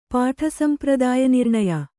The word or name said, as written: ಪಾಠಸಂಪ್ರದಾಯನಿರ್ಣಯ